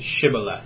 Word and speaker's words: Shibboleth: Shibboleth.